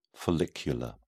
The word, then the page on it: follicular: British English